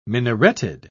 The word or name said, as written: minareted